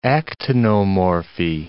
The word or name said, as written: actinomorphy